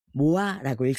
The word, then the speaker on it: MOA: 이 팬덤명 MOA, 모아라고 읽습니다.